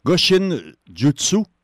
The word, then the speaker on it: Goshin Jutsu: C'est quoi l'épreuve Goshin Jutsu? Je le prononce bien, j'espère? – Oui.